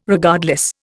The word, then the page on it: Regardless: Indian